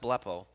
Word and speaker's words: blepo: That pay close attention, those three words translated in English, come from a Greek word called blepo, that pronounced blepo.